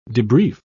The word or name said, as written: debrief